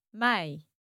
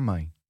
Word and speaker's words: mãe: Yep, and then we have mãe, ai. – Ai. – Mãe, that's it.